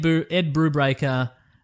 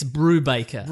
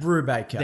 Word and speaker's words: Brubaker: I did say it wrong in my own defense just then. – Oh, did you? – It's Brubaker. – Brubaker. – There we go.